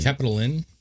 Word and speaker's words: Capitoline: And you had the Capitolian or Capitoline. I don't know if I'm saying that right. – Capitoline. – The hill. – The Capitoline. It's like a hotel.